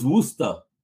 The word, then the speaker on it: Worcester: Yep, I'm in Webster now, but I used to almost pronounce it like that, and somebody told me, "No, it's Worcester." – Yep. It's Worcester or Worcester. Yep. – Yeah. – Definitely doesn't sound like it's written. But absolutely.